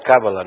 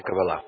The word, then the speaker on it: Каббала: Да, это американцы говорят Каббала, но вообще это Каббала.